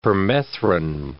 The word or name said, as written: permethrin